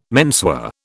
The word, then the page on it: menswear: British English